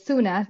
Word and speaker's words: Suna: So it's Suna, like sooner or later.